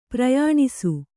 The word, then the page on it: ಪ್ರಯಾಣಿಸು: Kannada